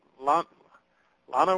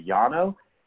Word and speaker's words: Llano: Yeah, it's the Llano if you're going to do it correct. If it's out of state, it's the Llano.